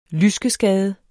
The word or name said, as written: lyskeskade